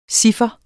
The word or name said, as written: chiffer